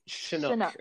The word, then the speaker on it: Chinook: Mm-hmm. I never know how to say it. Great. Chinook. Okay. – Chinook the Canuck. That's how he likes to say it.